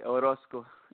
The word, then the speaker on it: Orozco: Okay, Orozco.